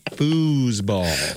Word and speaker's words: foosball: You're putting the wrong emphasis on the wrong syllable. – Okay. – Foosball.